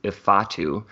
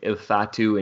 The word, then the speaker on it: Ifeatu: I believe the way he pronounced it was Ifeatu. And I think you said Ifeatu, which is different than Ifeatu, which is what I've been saying incorrectly, obviously.